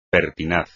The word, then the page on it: pertinaz: Spanish